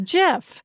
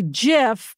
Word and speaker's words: GIF: I always thought it was GIF.